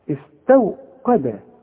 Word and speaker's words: استوقد: استوقد، استو شوف استو غلط، عارف دي تنطقها زي ايه؟ زي استوى، شوف استوى، استو قا، ما انا بقول لك ادي كل حرف حقه في القرآن، القاف قا، انما الواو اهي مرققة، والتاء احنا قلنا اتفقنا انها مرققة. استوقد، شوف واذا وقف استوقد، والدال دي من حروف القلقلة هناخدها ان شاء الله.